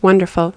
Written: wonderful

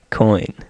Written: coin

